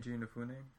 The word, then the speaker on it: Keiji Inafune: Keiji Inafune? – Yeah.